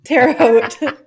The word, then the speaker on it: Terre Haute: So Jimmy played the violin in the Benjamin Franklin Elementary School Orchestra in Terre Haute. Did I do it wrong again? – You did. – Terre Haute. – Terre Haute? – Yeah, like H-O-T-E.